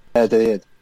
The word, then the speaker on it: Valladolid: Valladolid.